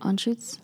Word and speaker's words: Anschutz: Is that how you say it? – Anschutz. – Anschutz? Oh, sorry.